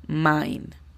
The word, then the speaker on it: mine: La pronunciación fonética de esta sería M-A-I-N. Mine.